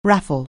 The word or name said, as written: raffle